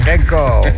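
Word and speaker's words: Renceau: Renko.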